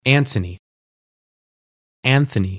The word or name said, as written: Ant(h)ony